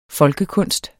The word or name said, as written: folkekunst